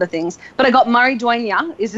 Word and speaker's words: Maridueña: It's a little difficult because of the X, but it's pronounced Xolo Maridueña. – Xolo Maridueña.